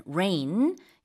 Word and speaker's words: rein: Rain이라는 거는요, 발음은 우리 하늘에서 내리는 비처럼 들리지만 스펠링은 지금 알려주신 대로 달라요. REIN, rain.